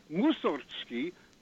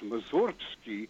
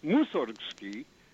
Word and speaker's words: Mussorgsky: And also, on the in the same country, Modest Mussorgsky, with emphasis on the first syllable, and it's often pronounced Mussorgsky. And as Richard Taruskin has mentioned in the course of his lectures, it should be Mussorgsky.